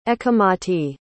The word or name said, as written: ecchumati